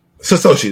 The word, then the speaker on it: Satoshi: Satoshi, there we go.